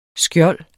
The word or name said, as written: skjold